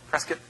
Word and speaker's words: Prescott: I'm told it's Prescott, not Prescott. So Prescott.